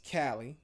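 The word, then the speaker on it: Kali Uchis: It's Kali, which is Callie. – Mm-hmm. – And then it's Uchis.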